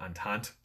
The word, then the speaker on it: entente: Okay, and then on the other side you have what's called the um, entity cordiale, which I believe I said it said it correctly. Is it entente? Entente. Yeah, I'm sorry.